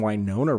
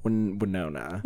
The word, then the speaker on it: Winona: It's either a short I or a long I. You're giving it a long I. Like Winona Judd.